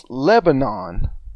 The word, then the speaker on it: Lebanon: Lebanon. – It's Lebanon as it's spelled, but when you go up there, it's Lebanon.